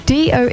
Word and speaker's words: DOE: We've got DOE. It's spelled DOE, but it's pronounced Doe. Full name is Doe.